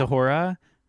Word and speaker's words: A'Whora: Every time you say it, there is a drag queen who was on UK Drag Race season 2 whose name is Ahura, but it's spelled A-W-H-O-R-A, like Ahura.